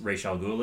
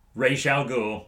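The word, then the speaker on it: Ra's al Ghul: Yeah, he has to get everyone out of the mansion because Rachel Ghoul is there. – That mm-hmm. is how it's pronounced. Ray al Ghul.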